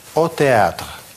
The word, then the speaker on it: au théâtre: Сочетание А, У читается по-русски О. О театр.